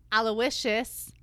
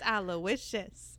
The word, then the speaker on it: Aloysius: Because we all know Aloysius rhymes with Fergalicious. – Uh-huh. – Oh. – So it was going to happen, but it just... – It's Aloysius.